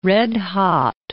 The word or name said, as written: red-hot